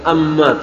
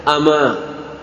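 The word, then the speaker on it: ama (أما): Qala fi Mughnil Labib, jadi penyusun kitab Mughnil Labib mengatakan, ama bil fathhi wat takhfif, dengan fathah dan dengan dibaca ringan, tidak tasdid, bukan amma tapi bacanya ama.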